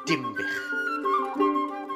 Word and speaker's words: Dinbych: And they called it Dinbych, which in modern Welsh comes down as Dinbych, although the people who live there still pronounce it with the M, Dim.